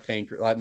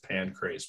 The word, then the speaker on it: Pancrase: Pancrase? Is it spelled like pancreas? – It's P-A-N-C-R-A-S-E. – Pancrase. Oh, okay. So not pancreas. Not like the.